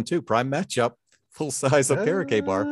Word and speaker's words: Okereke: I'm going to have Leonard and Okekie as linebackers versus Derrick Henry. – Okereke is the new pronunciation.